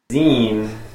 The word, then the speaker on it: zine: Zine, spelled Z-I-N-E, pronounced zine, derived from magazine, is an independently or self-published booklet often created by a single person.